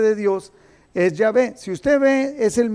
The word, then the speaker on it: Yahvé: Entonces, los hebraístas, o sea, la gente que estudiaba hebreo, han llegado al acuerdo general de que la pronunciación original debe de haber sido Yahvé.